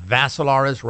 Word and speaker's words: Vassilaris: And Dimitri is spelled with three I's, D-I-M-I-T-R-I. The last name, Vassilaris, rhymes with Assilaris.